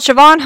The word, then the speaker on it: Siobhan: She is called Siobhan Holmes. It is spelt the Irish way. S-I-O-B-H-A-N. Yes, it makes no sense. It's Irish. Deal with it.